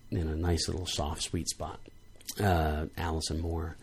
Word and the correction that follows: Moorer: and that is spelled M-O-O-R-E-R, Moorer, in case you wanted to look her up.